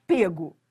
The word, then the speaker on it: pego: Em regra, deveria ser só pego, porque lá no início a forma, a pronúncia era fechada, pego, mas hoje liberou geral.